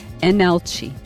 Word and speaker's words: Leenalchi: So it's spelled with an L, but it's pronounced Enalci. L-E-E-N-A-L-C-H-I.